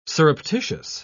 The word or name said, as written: surreptitious